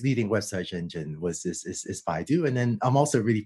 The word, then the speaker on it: Baidu: And is it pronounced Baidu? Forgive me for not knowing. – Oh, yes. I started and led the Google Brain team. I also ran AI for Baidu, which is a large web search engine company in China.